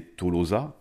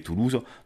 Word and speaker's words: Toulouse: je lisais Tolosa, et en fait, j'ai compris que c'était pas du tout ça, la prononciation, c'était Toulouse.